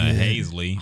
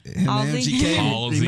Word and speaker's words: Halsey: An abuser, allegedly, according to Haisley. – Hosley? – How the fuck you say her name? – Halsey.